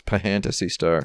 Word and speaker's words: Phantasy Star: Actually, in my interview, I asked her how it's supposed to be pronounced, and she said it is supposed to be pronounced Fantasy Star.